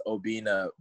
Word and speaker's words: Obinna: It's Obinna, but nah, you were close enough.